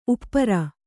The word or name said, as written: ಉಪರಿ